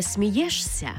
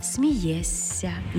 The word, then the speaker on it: смієшся: Також пишемо «жся», а вимовляємо «ся». Наприклад, не «дивишся», а «дивися». Не «смієшся», а «смієсся». Не «спілкуєшся», а спілкуєшся.